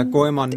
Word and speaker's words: Goemon: Ähm, ja, Goemon.